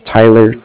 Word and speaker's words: Tyler: Again, my name is Tyler, T-Y-L-E-R, and I'm calling with Cardiff. It's spelled C-A-R-D-I-F-F, as in Frank.